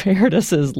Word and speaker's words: Erdős: But the story of Erdős's life, if that's how you pronounce his name, Erdős, yes.